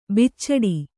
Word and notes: ಬುಚಡೆ: bucaḍe

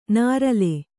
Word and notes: ನರಲೆ: narale

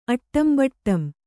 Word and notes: ಅಟ್ಟಂಬಟ್ಟಂ: Kannada

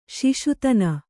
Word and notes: ಶಶಾದನ: Kannada

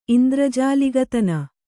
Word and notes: ಇಂದ್ರಜಾಲಿಗತನ: kannada

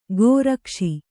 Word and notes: ಗೋರಕ್ಷಿ: gōrakṣi

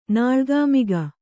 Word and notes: ನಾಳ್ಗಾಮಿಗ: Kannada

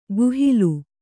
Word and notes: ಗುಹಿಲು: guhilu

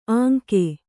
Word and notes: ಅಂಕೆ: Kannada

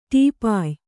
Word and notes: ಟೀಪಾಯಿ: Kannada

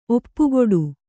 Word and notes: ಒಪ್ಪಗೊಡು: oppagoḍu

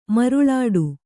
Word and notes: ಮರುಳಾಟ: kannada

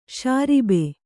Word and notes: ಷರಾಬು: ṣarābu

